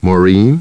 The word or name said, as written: Maureen